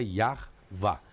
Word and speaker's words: Yahvá: Então a pronúncia mais correta do tetragrama seria Yahvá.